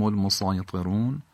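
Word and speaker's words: المسيطرون: سین در کنار طا که قرار گرفته به خاطر درشتی طا سین با صاد هم تلفظ میشه توی این کلمه لذا تلفظ این کلمه هم به اون شکلی که نوشته شده با صاد صحیحه ام هم المسایطرون هم با سین صحیحه هر دوش در زبان عربی کاربرد داره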